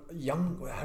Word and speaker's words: Young Ho: For my kicker, we both had him last week, going Young, how do you say it? Young Hao Koo? – No, it's Young Ho. – Ho. Wait, but it is Young Hao, I think, but we like to say Young Ho.